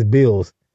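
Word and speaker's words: Billz: It was like B-I-L-I-Z. – No, it's B-I-L-L-Z. That's Bills. Bill, a song called Different. – Okay. I thought that was So how you feel about that song, Bill's Different? – I said 8 out of 10.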